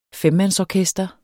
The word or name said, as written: femmandsorkester